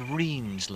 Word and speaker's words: rhyne: If you look from the air, it would just be glittering sort of stripes of ditches and what they're called reens locally. It's spelled R-H-Y-N-E.